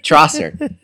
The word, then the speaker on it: Trossard: Silent D. That's Leandro what I heard. – Trossard. – Yeah. – Trossard. – I didn't hear that. I heard a silent D from the announcers.